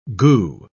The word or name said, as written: goo